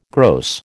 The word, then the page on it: gross: American English